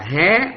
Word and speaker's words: hạ: Rồi hạ cũng vậy, hạ nó là nghĩa là trạng từ đó, đọc là hạ nghĩa là ở dưới, nhưng mà có trường hợp người ta đọc là hé, là bởi vì nó có nghĩa là đi xuống nha.